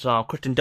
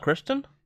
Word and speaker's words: Kirsten: It has Kirsten Dunst. – Kirsten. – What? – Kirsten. – Kirsten. It's not Kristen? – No.